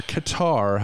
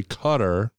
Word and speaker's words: Qatar: I'm looking forward to the World Cup in November in Qatar, or Qatar, how I pronounce it, but it's actually Qatar,